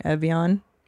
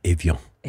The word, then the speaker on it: Evian: Say this when I say it wrong? Evian? Avion? – Avion.